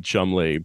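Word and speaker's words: Cholmondeley: I mean, an Englishman would have have to defend why you pronounce Edinburgh, Edinburgh, and Chalmondeley, Chumley.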